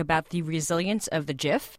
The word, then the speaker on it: GIF: I personally prefer GIF, the hard G pronunciation.